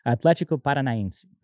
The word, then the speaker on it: Paranaense: Paranaense, right? Am I pronouncing it right? – Yeah. – Am I pronouncing it right? – Atlético Paranaense.